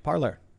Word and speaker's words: Parler: Apparently now it is called Parler, even though it's spelled Parlay. – Say it. Tell me. – Parler. – Tell me. What? – It's pronounced Parler.